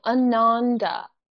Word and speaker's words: Ananda: Soft A's. So Ananda.